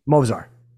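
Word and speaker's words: Movsar: Mozar.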